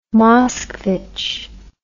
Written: Moskvitch

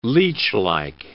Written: leechlike

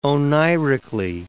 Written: oneirically

